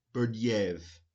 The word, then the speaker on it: Berdyaev: 1948, death and exile of Nikolai Berdyaev. I said that wrong. Berdyaev. just Berdyaev, almost like it might be French, a Russian Orthodox political theorist and associate of Jacques Martin and his circle.